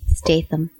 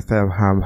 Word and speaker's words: Statham: Statham. – Statham. I just can't say his name. – There's right. – No R.